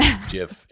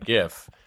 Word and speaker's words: GIF: You know, as I understand it, the person who invented it said it's supposed to be pronounced that way. But many people still persist with GIF.